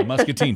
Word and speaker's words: Muscatine: We'll start in Moline and go to Muscatine. – Oh, boy. – And I don't know if I'm saying that right. – Yeah, Muscatine, sure. Yep, absolutely.